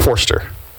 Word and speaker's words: Forster: I've been saying his last name wrong, but Robert Forrest. Is it Forrest? – I mean, Forster. – No, it's Forster. It is Forster.